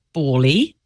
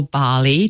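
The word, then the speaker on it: Ballie: I was excited by Bali, or Bali, depending on your pronunciation, which was Samsung's new home robot, which had actually been announced a few years back, but has come with more features.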